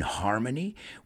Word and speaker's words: harmony: Let's pronounce that one. Armonía. We pronounce the H in English. Can we live in harmony with the technology we've created?